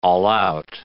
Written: all-out

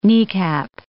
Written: kneecap

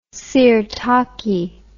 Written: sirtaki

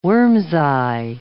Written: worm's-eye

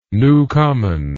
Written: newcomen

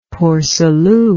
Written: Port Salut

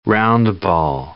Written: roundball

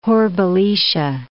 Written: Hore-Belisha

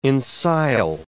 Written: ensile

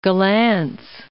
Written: Gollancz

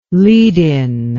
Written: lead-in

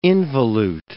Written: involute

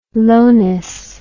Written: lowness

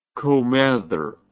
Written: comether

